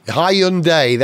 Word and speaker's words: Hyundai: People are saying that Hyundai, hang on, let me say it right. Hyundai, that's what it is now.